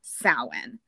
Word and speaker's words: Samhain: It's spelled Samhain, but it is pronounced in the Gaelic pronunciation Samhain, which I can't tell you how you get that from that spelling, but that's because I'm not Gaelic.